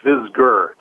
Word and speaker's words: Visger: Hey, real quick, my name's pronounced Visger, just for reference, so not a big deal.